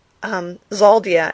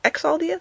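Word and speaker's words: Xhaldia: It says, on the planet, I guess it's pronounced Xaldia. It's X-H-A-L-D-I-A. So I'm thinking Xaldia. Exaldia? Xaldia.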